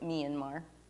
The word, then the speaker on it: Myanmar: It looks like Myanmar, but they pronounce it Myanmar.